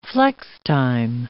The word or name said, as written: flextime